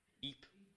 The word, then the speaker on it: Ypres: You know, I remember reading about this Belgium town, which I can't pronounce, but it's Ypres. Is that right? Y-P-R-E-S? Ypres. Ypres. Okay, well, there we go.